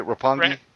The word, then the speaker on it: Roppongi: Did I say that right? Roppongi. – Yep, it's the district in Japan where they party their asses off.